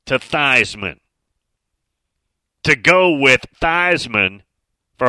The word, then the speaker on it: Theismann: But he said, yes, I was told by somebody in the SID department back in the early 70s that it's going to be Theismann, which rhymes with Heisman.